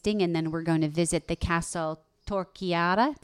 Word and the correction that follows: Torrechiara: Did I say that right, Val? – Torrechiara. Yep.